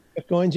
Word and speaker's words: Goins: Jeff Goins, is that how you say his name? – Jeff Goins, yeah.